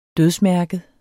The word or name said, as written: dødsmærket